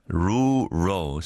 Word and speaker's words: rural: Rural, rural, 시골. R-U-R-A-L, rural. 지금도 안 되죠. Rural, 막 우우 막 그런 거 같죠.